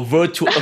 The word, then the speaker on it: virtuoso: It's an assertive virtuous soul. – No, virtuoso. Okay, it's spelled V-I-R-T-U-O-S-O. It's a virtuoso.